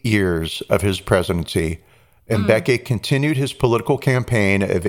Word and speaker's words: Mbeki: During the presidency of, I'll mispronounce his first name, Thabo Mbeki. Mbeki, that's it, Mbeki.